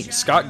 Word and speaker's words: Scott: And hey, you can friend me, Scott Gardner, on Facebook too.